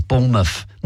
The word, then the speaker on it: Bournemouth: They don't really pronounce the mouth bit. We go Bournemouth.